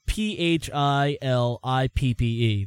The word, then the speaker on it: Philippe: Well, it's spelled P H I L I P P E. That's Philippe.